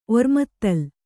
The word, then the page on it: ಒರ್ಮತ್ತಲ್: ormattal